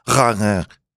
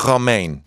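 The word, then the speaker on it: Romijn: Her name is, here in the United States, they say Romaine. – Uh-huh. – But I learned in Holland, where my wife is from and her people are from, it's actually pronounced Rangin. – Oh. – I'm kidding. I really exaggerated that. Everyone, you guys thought it was real. It's pronounced Rebecca Romaine.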